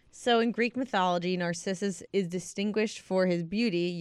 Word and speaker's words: Narcissus: Narcissus. Am I saying it right, Alan? – Narcissus. Yeah.